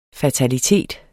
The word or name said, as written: fataliteter